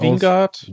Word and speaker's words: Wingard: Dann hier Death Note, ähm, der ja von Adam, äh, spricht man ihn Weingard oder Wingard aus? Wingard. – Wingard. Ja.